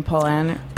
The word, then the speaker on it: Paulaner: Paulaner. – It sounds like Pollyanna a little bit.